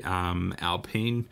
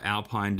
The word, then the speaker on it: Alpine: but they're rebranding to Alpine, or Alpine depending on where you're from and how you want to pronounce it, from 2021.